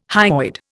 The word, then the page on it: hyoid: Indian